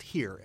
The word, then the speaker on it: Hir: Could you tell us a little bit about Her? Is that the correct pronunciation of it? – It's actually pronounced here. – Here, okay. – Yep, H-E-R-E. Sounds like here, spelled H-I-R.